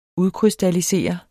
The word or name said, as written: udkrystallisere